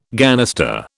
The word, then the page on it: ganister: British English